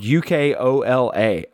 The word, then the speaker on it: Ukoala: And this little, it's called, it's spelled Ukoala, O-A-L-A.